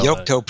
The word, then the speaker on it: Yocto: is that pronounced Yocto or Yocto? No, Yocto project and Open Embedded.